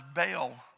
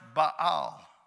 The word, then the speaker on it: Baal: He had married a Sidonian woman whose father was the chief priest of the god that we mispronounce as Baal. It's pronounced Baal.